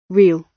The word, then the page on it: real: British English